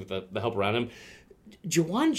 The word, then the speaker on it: Jauan: Yeah, one of the weirder ways to spell Juwan. He is back, yeah. – Is that how you say it? – No, that's how you say it. He just spells it J-A-U-A-N.